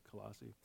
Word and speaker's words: Colossae: And the city of Colossae, I don't know if I'm saying that right. It's Colossae. – Close enough.